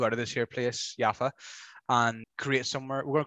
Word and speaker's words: Jaffa: So Jaffa, or pronounced Yafu, is an ancient port city situated in the south of Tel Aviv.